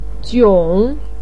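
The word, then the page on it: jiong: tone 3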